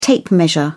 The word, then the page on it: tape measure: British English